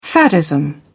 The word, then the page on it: faddism: British English